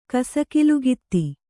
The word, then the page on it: ಕಸಕಿಲುಗಿತ್ತಿ: Kannada